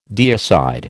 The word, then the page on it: deicide: American English